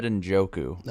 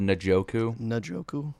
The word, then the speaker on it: Njoku: I said his name right that time, guys. David Njoku. – Nice. – That's right? – Yep, that's right. – Not David Njoku.